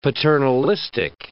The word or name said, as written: paternalistic